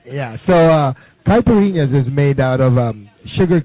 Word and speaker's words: caipirinha: It's caipirinha. – Caipirinha? – Caipirinha.